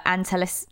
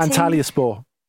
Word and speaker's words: Antalyaspor: Well, let's hope his debut goes better than Shoya Nakajima's debut for Antalis. I don't know how you say that, Andy. How do you say that? – Antalyaspor.